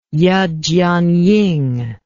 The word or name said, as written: Ye Jianying